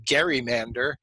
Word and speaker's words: gerrymander: So I insist on saying Gerrymander because Gary, it was his name. But a lot of people will absolutely insist on saying Gerrymander, even though it's ridiculous.